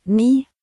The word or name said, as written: 9